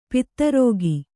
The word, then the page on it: ಪಿತ್ತರೋಗಿ: pitta rōgi